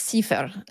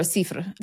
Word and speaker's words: sifr: I believe it's sifr or sifr.